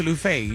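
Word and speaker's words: Luffeigh: Like Luther Lufe, you know, it's L-U-F-F-E-I-G-H. The first F is silent.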